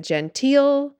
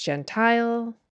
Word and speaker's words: Gentile: I mispronounced Gentile, as in a non-Jewish person. I said genteel. It's pronounced Gentile.